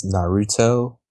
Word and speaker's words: Naruto: I believe it's pronounced Naruto. – Yeah, so I said Naruto. – You said Naruto. – Naruto. I'd not say Naruto. Whatever.